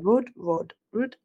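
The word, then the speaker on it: Roord: Is it Rood? I don't know how you say her name. Yeah. Rood.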